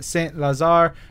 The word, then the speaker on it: Saint Lazare: Raph AB posted a picture and he says, love this poster on Saint Lazare train station in Paris.